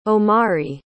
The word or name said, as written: Omari